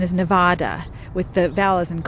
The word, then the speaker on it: Nevada: East of the Rockies, they'd say Nevada. West of the Rockies, you typically hear Nevada because we know how to say it.